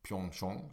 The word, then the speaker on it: Pyeongchang: I det som heter Sweden Arena, som är ett sånt Sverigehus som de har i Pyeongchang, som jag lärt mig att det ska uttalas.